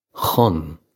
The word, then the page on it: chun: khun; Irish